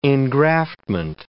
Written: engraftment

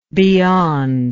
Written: beyondness

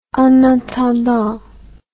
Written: en attendant

